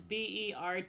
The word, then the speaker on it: Berthoud: Some people pronounce that birthed. – No. – Nope. – It's Berthoud.